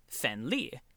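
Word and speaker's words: fen li (分梨): The Chinese word for the pear is li, and the Chinese phrase for sharing a pear with someone translates as fen li. Fen li, coincidentally, is also how you pronounce the Chinese word for separation.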